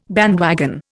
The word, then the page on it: bandwagon: Indian